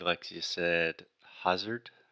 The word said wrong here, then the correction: hazard: So that first sound is the ah. Just like dad, master, disaster. So the correct pronunciation is hazard.